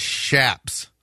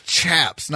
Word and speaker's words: chaps: Somebody said it's pronounced shaps. No. – No. – No. You're totally wrong, sir. – I'm sure it's pronounced humble, chaps. Too, instead of humble. – It's chaps, not shaps.